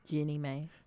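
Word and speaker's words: Jeannie Mai: Jeezy and Jenny Ma. That's how you say her name? – Jenny May.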